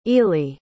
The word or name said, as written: ely